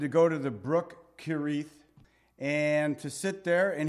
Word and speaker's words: Kerith: And that is how you say it, Kerith, the Hebrew.